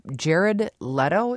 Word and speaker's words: Jared Leto: And the supporting actor, in from last night's awards was also from Dallas Buyers Club, Jared Leto, is it pronounced? – Mm-hmm.